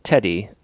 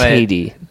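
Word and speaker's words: Teti: It's not Teddy. That's not how you say it, right? – It's Tatey, but Tatey. – Teddy's fine too.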